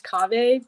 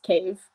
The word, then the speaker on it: Cave: And yes, my name is Cave, but it's just Cave.